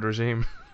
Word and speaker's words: regime: What did I say? – Yes. – Resume? – Resume. – Regime. – It's not quite a resume, but it's close.